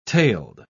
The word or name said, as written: tailed